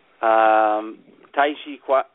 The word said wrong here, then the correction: Tai Chi Chuan: Tai Chi Chuan.